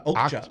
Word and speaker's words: Okja: Okja.